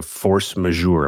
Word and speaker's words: force majeure: You've been tweeting a bunch about this, the idea of force majeure.